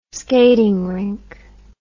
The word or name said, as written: skating rink